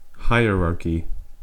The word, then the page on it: hierarchy: American English